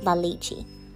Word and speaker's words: La Leche: It's L-A space L-E-C-H-E, La Leche.